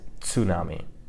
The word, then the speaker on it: tsunami: It's kind of, in English, we don't pronounce the T. We just say tsunami, but in Japanese, the way it's correctly pronounced is tsunami, right? So this is a ts, T-S sound.